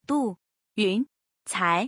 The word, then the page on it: 杜云才: Du Yuncai